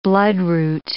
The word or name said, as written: bloodroot